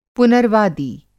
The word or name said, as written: पुनर्वादी